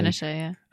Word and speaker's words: Pinochet: Am I pronouncing it correctly? – Pinochet, yeah.